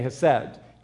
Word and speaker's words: hesed: The word loving kindness there, for your notes, for the note takers, the word loving kindness there is the Hebrew word hesed. The pronunciation has that guttural German hesed, but just say hesed. H-E-S-E-D.